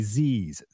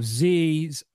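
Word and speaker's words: Ziz: It's actually Z's, Z-I-Z, pronounced Z-I-Y-Z.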